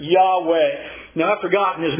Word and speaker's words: Yahweh: We think the best pronunciation for this would be Yahweh, what is sometimes called the Tetragrammaton.